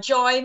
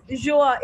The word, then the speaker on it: Joie: Will tell you the right pronunciation. It's okay. Joie. Everyone gets it wrong. – It's Joie. It's a French word for joy.